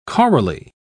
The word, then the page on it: chorally: British English